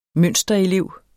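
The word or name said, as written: MØNSTERELEV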